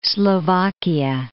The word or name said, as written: Slovakia